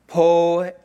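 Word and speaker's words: poiema: Now, the word workmanship in Greek is pronounced this, poeam. Can we say poeam? Poeam. Yes, people are awake.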